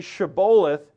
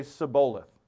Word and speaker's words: Shibboleth: And they had become so divided that in Ephraim, to say Shibboleth, he couldn't pronounce the sh. He could only say Sibboleth.